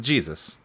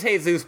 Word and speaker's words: Jesus: Nobody fucks with the Jesus. – Yeah. – Right, okay, yes. – His name's Jesus, but he pronounces it Jesus.